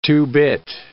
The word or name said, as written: two-bit